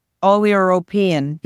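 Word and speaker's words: oleuropein: I've noticed, for example, in olive leaf extract, a 500 milligram dose, the amount of oleopein, did I say that right? – Oleoropein. Yep.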